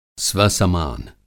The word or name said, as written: स्वसमान